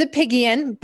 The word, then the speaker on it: Apigian: It's Apigian, but you know what? Apigian. Mm-hmm. Apigian. Like the three little pigs.